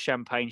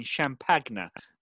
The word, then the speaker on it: Champagne: Or I like champagne, but not pronounced champagne, Champagna.